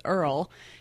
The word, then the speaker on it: URL: His name is Earl. He's an 8-pound terrier puppy, and his name is spelled U-R-L, Earl.